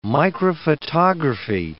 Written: microphotography

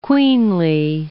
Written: queenly

